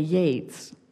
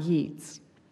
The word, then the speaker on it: Yeats: Beside the key, I learned the quay, I learned to pronounce as key, as I knew to say Yates, not Yeats.